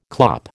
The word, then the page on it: clop: American English